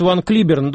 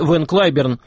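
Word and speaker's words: Van Cliburn: Минувшим вечером в Соединенных Штатах скончался выдающийся американский пианист Ван Клиберн, другой вариант произношения его имени – Вэн Клайберн.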